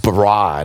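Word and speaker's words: bara (ברא): The Hebrew word that's translated as created, that God created, is pronounced bara, and it just simply means to form, just to take something and shape it and form it, to work it.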